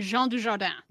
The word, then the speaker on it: Dujardin: It was Berenice Bejo and Jean Dujardin.